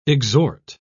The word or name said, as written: exhort